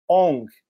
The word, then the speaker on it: Aung: que é Cho Kyaw Aung, A-U-N-G.